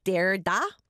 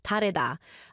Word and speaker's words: 다르다: If we look at the way it's spelled, a lot of us are going to be like, Dare-da? That is not how you pronounce the artist's name. 다르다.